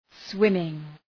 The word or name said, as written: swimming